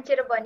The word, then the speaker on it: Cirebon: ஆண்டவர் என்னை அழைத்து இவனமாக சிரபோரில் ஒரு ஊழியம் ஒன்றை துவங்கச் செய்தார் Did I did I pronounce the the the name of the place correct? – Yeah, Bekasi and Cirebon. – Yes. Yes, yes.